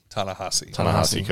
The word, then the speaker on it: Ta-Nehisi: Ta-Nehisi Coates.